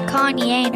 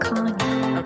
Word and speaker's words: Kanye: Pronounce Kanye, not Kanye.